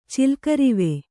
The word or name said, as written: ಚಿಲ್ಕರಿವೆ